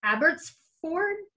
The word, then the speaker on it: Abbotsford: Marjorie is a wife, mother, and grandmother who lives in Abbotsford, BC.